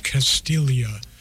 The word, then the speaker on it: Castiglia: That last song was Slumlord Billionaire by the Florida singer and guitarist Albert Castiglia, spelled C-A-S-T-I-G-L-A-L-I-A. C-A-S-T-I-G-L-I-A, the G is silent, and that is from Albert Castiglia's brand new record, Grits and Glory, on Gulf Coast Records.